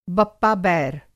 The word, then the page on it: बप्पा-बैर: Hindi